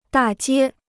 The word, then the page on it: 大街: dà jiē